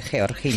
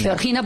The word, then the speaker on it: Georgina: Es que lo ha pronunciado como debe, Georgina. – Georgina, porque ella es de Jaca.